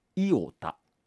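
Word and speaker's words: Iota: イオタって読むの、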